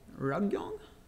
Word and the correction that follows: Ryugyong: Is that how you would pronounce it? – Ryugyong. Yeah, hotel. – Ryugyong.